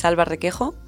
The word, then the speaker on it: Alba Requejo: My name is Alba Requejo, as Peter tried to pronounce.